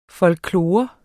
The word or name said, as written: folklore